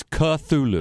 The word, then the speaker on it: Cthulhu: which I mispronounced with a ch sound. So I, I apologize we're not big Lovecraft fans. So, but I've always loved the spelling of that word, but obviously I didn't know how to say it. – So it's Cthulhu. That's according to Silas.